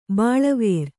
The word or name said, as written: ಬಳ್ಳಿವರಿ